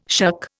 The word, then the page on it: shuck: Indian